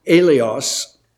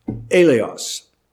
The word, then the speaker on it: eleos: It is pronounced alios, and I'll spell it with English letters so that you can see it more clearly. The letters would be E-L-E-O-S, alios.